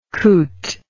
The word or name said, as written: coot